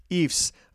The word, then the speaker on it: Ephs: That is spelled E-P-H-S, but it is pronounced indeed Eefs.